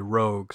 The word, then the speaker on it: Roeg: I've see I've always heard it pronounced Roeg, and I guess I was I was corrected when I listened to the features, and they actually say Rogue. So, uh, excuse my my flub there.